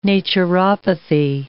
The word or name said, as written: naturopathy